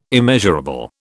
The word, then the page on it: immeasurable: British English